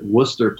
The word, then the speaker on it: Worcester: So for me, I was just going to say that I know that we pronounce it Worcester Poly, not like Worcester Poly.